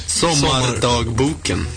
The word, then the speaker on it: Sommardagboken: Il nuovo singolo si intitola Sommardagboken. What's the correct pronounce of the EP? – Sommardagboken.